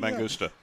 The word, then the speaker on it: Mangusta: That is a Mangusa. Does that say it right? – Mangusta. Yeah.